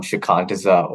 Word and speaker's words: Shikantaza: Shikantaza? – It's a Buddhist practice. It goes back centuries, commonly referred to as Zazen, but the modern term has become Shikantaza, or in layman's terms, just sitting and doing nothing.